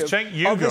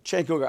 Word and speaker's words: Cenk Uygur: Get his name right. His name is Cenk Uygur. I told you three times now. – I'm sorry, Cenk Uygur.